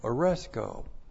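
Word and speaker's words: aresko (ἀρέσκω): A-R-E-S-K long O. Aresko.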